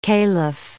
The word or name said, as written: calif